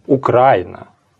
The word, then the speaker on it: Украина: Правильно, оно так и должно. Украина.